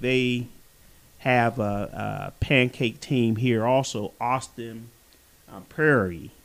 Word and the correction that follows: Austin Peay: if I pronounced that correctly. – Prairie. – Austin Peay.